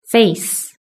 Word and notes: face: British English

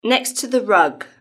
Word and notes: next to the rug: British English

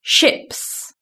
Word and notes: ships: British English

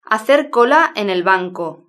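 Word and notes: hacer cola en el banco: Spain

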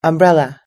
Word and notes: umbrella: American English

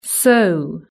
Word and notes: so: British English

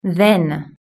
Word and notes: then: British English

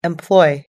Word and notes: employ: American English

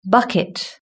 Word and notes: bucket: British English

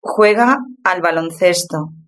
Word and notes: juega al baloncesto: European Spanish

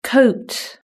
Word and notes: coat: British English

